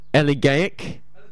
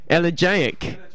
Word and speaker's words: elegiac: The third word I read, I can't pronounce. Elegiac. – Elegiac. – Elegiac. Thank you.